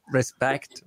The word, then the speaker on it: respect: Non so, res, respect, res, res, respect, è l'enfasi. La pronuncia è perfetta è l'enfasi se su re o spect.